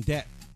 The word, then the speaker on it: debt: It's funny how you pronounce the B in debt. The B is silent.